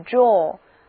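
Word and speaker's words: drawer: draw 意思是画画、拉，那么拉出来的东西抽屉就是 draw，注意后面加 er，但是发音不变。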